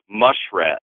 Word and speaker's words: muskrat: Actually, my father tells me that in downriver, it's pronounced mush rat, and refers not only to the murky little water rat, but